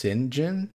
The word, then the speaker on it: Cyngn: It's now known as, I guess pronounced Cyngn. It's spelled C-Y-N-G-N.